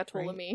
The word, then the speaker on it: Ptolemy: Yeah, Ptolemy. – Right? Yeah. – So rather than following, is it Ptolemic? – Yeah, sure, why not? – Rather than following Ptolemy